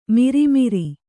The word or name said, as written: ಮಿರಿಮಿರಿ